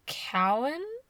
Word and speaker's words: caoine: The scream was called Cowan. C-A-O-I-N-E.